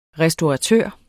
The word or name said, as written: restauratør